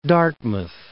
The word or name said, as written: Dartmouth